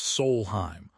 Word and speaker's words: Solheim: Analytics in team golf like the Solheim Cup, pronounced Solheim, are a tool, not a Bible.